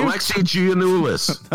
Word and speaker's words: Alexi Giannoulias: Alexi Giannoulias. – Oh, thank you. – I forgot to put the thing back on. We'll just take that out of the podcast. – There we go. Alexi Giannoulias.